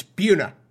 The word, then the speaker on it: Buna: All right, get it right. It's Buna. All right, I know that. I just like saying it the way it phonetically looks.